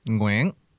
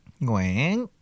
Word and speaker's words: Nguyen: Nguyen. Nguyen. With that tone, too.